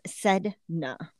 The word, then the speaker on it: Sedna: So, her name is pronounced Sedna.